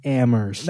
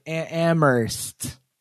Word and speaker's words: Amherst: That's Amherst. Right. That's it. – Excuse me, the H is silent. Amherst. – That's right. Amherst.